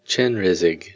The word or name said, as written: chenrezig